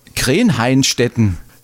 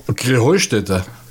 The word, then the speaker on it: Krähenheustätte: Ja, ihr saget immer Krenheinstetten. Und dabei heißt es doch Krähenheustätte, oder wie? – Krähenheustätte. – Ja, genau, und das ist die Betonung auf dem Heu.